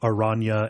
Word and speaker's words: Oranje: So it's Revachol, Oranje, and Klaasje.